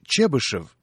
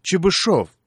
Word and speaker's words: Чебышев: Но кто, например, знает, что правильно говорить «Ришелье», а не «Ришелье», «Монтескье», а не «Монтескье», а великий русский математик Пафнутий Львович Чебышев на самом деле Чебышев.